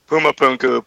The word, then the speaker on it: Puma Punku: Puma Punka? I always think I say it wrong. – Puma Punku, P-U-M-A-P-U-N-K-U. – Puma Punku. – Puma Punku. – Puma Punku. Puma Punku. – Puma Punku. – Puma Punku. Okay, I got it.